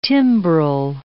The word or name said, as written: timbrel